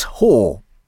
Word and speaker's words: Hoa: It's spelt H-O-A, but in South Korea, it's Whore.